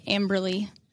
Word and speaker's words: Amberlee: It's just my first name Brin and then Amberlee.